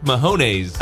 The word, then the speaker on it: Mahomes: He's the Super Bowl guy who I kept mispronouncing his name. – I believe Patrick Mahomes. – Mahomes, yes. – Which there's no N in the middle of his name.